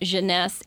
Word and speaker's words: Jeunesse: Well, let's talk about Jeunesse, and I want to start talking about the Instantly Ageless Cream.